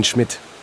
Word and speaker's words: Schmidt: Yes, it's Armin Schmidt. – How do you spell that? – S-C-H-M-I-D-T.